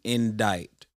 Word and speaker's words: indite: So the word of the day is indict. I-N-D-I-T-E.